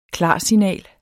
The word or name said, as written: klarsignal